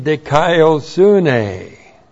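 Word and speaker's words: Dikaiosune (δικαιοσύνη): resulting in dikaiosune. That's the right path. D-I-K-A-I-O-S-U-N, long E. Dikaiosune, that's the Greek word righteousness.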